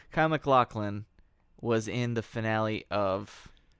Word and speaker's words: MacLachlan: I just watched the- I just watched a YouTube video on how to pronounce his name and it's MacLachlan.